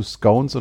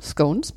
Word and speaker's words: Scones: Äh, natürlich zu Scones und Clotted Cream hatten wir damals bei der Brexit-Ausgabe auch. – Richtig ausgesprochen diesmal, Scones.